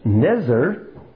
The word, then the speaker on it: Nezer: Others, however, have said no, it probably comes from a very similar sounding Hebrew word pronounced Nezer, from which we get the word Nazarite.